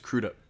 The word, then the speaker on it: Crudup: It's Crudup.